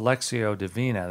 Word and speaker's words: Lectio Divina: it's called Lectio Divina. That's how the Latin is pronounced. It's spelled L E C T I O D I V I N A, and it means in Latin divine reading.